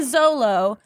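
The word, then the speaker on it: Xolo: A Mexican Zolo. – But it's spelled with X's.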